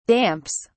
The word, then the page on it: damps: British English